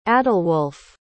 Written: adalwolf

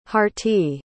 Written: harti

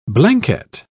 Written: blanquette

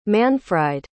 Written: manfried

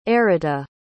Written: errita

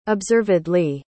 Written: observedly

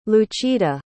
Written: lucida

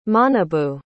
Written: Manabu

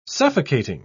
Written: suffocating

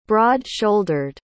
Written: broad-shouldered

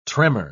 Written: tremor

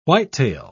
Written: whitetail

